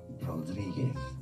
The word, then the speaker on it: Rodriguez: Spain, it is pronounced as Rodriguez. Okay, so let's break it Rod down. Ro Rodriguez stress on this.